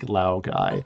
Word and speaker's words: Laogai: Laogai. – Wow, guys. – Laogai.